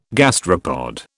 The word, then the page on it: gastropod: British English